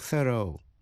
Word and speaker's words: thorough: Nhớ là R-O-U-G-H, trong thorough, đọc là thorough, theo giọng Anh. Thorough, theo giọng Mỹ.